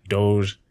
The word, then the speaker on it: Doge: Are you getting into, at the time, we didn't even know how to, you know, we don't have to pronounce doggy. The name like doggy. It's like Doge.